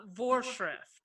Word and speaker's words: Vorschrift: especially the Vorschrift. Am I saying that right? – Vorschrift.